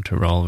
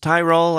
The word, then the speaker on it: Tyrol: You know Tyrol, right? I've heard that also pronounced as Tyrol. I've heard it pronounced as Tyrol. It's an alpine region in Austria.